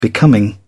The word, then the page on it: becoming: British English